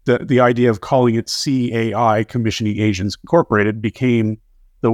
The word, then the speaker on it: CAI: but we call it CAI. You might hear people try to pronounce it as one word, but it's technically three letters.